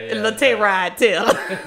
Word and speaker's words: Tyrod Taylor: Tyrod Taylor. – No, well, yeah. Black people say it different. – Yeah. – Tyrod Taylor. – No, you said it right.